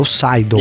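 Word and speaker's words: Seidl: Tem o Adrian Seidley, né? Então o Adrian Seidley, na verdade, chefe de equipe da, Ma da McLaren, o Garcia. Eu falei errado, né? É o Seidel.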